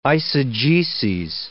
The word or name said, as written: eisegeses